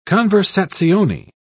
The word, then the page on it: conversazione: British English